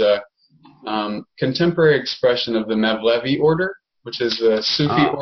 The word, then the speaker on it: Mevlevi: I didn't know that you were more formally involved with the, help me pronounce it right, Mevlevi. Yeah. – Mevlevi, yeah.